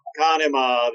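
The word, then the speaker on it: Conemaugh: But to this day, I'm still not sure how to pronounce Kanemawaho. Where the hell were you guys from? – Kanema. – There you go.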